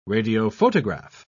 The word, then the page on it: radiophotograph: British English